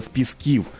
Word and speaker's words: Пісків: Галино, а знаєш, я звернув увагу, що ти правильно сказала «з пісків». Тому що так. У нас наголос дуже часто журналісти ставлять інакший.